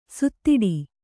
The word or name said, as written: ಸುತ್ತಾಡು